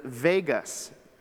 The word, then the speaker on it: vagus: But it's the vagus nerve. Not like Las Vegas. Vagus, I think, is actually how you say it.